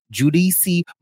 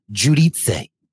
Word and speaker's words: Giudice: Teresa Giudice, but you know how she switched her name up because in season 3 of New Jersey, all of a sudden they were like, it's pronounced Giudice. So then they were going by Teresa Giudice, and then they went back to Teresa Giudice.